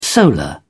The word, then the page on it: solar: British English